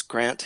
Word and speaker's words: Grant: Well, yes, my grandfather pronounced it Grant because he was from Aberdeen, but my UK cousins say Grant, but over here we say Grant.